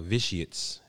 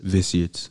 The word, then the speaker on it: Vitiates: Nothing the way it sounds or spelled. – It's a weird title, but once it catch on, you'll catch on. Vitiates. – Vitiates. Yeah. Spell it. – V-I-T-I-A-T-E-S. – Okay.